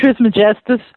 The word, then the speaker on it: Trismegistus: Hermetic Trismegistus.